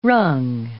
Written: wrung

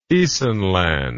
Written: Isenland

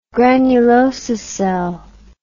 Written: granulosa cell

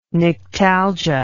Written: nyctalgia

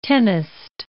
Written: tennist

